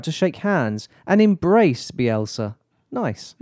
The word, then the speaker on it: Bielsa: Sammy Lee, who is the assistant West Brom, assistant boss at West Brom, he had a unique request for Marcelo Bielsa. – Yeah. – Am I saying that right? – Bielsa? – Bielsa, sure.